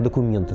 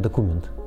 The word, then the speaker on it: документ: Ну вот, например, правильный документ, а совсем неправильный документ.